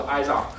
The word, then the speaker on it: Azure: 归根结底的话，cloud 不就是一个资源池吗？cloud 的话，然后就像大家在用，不好意思，我还用大陆发音，我们叫 Azure。